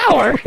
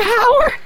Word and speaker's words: hour: Mentioned several times for about an hour. Yeah. – The H is silent in an hour. – Did I say Howard? – Yeah.